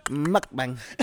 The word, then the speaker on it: mukbang: That's what it's called. Mukbang. That's how you say it, bro.